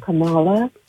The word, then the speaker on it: Kamala: I was calling because I agree with Danny about Kamala. I don't think that black Her name is pronounced Kamala. – Kamala. Mm-hmm. I always get it wrong.